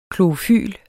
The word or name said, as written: klorofyl